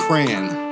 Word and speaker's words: crayon: You look it up in the dictionary and it says proper pronunciation. Cray. on. – Like pray on.